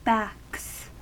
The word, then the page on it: backs: American English